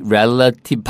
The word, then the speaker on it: relative: 자, 그래서 포인트는 여기서는 R-E-L-A-T-I-V-E. Relative. 발음이 relative가 아니고요. Relative예요.